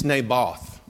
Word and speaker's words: Naboth: It's Naboth.